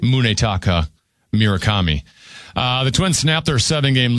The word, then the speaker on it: Munetaka Murakami: Munetaka Murakami in the 8th.